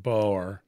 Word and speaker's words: Boer: I didn't realize it was a two-syllable word. – I don't think you say Boer. I think you say Boer. You give it a little like rawr on the end, Boer.